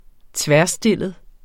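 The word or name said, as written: tværstillet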